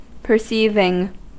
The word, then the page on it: perceiving: American English